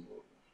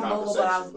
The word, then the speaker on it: Louisville: Like, I'm correcting people on how to say it when I go out of town. – Ain't that crazy? – All the way from Louisville. I said, there's no E right there, bro.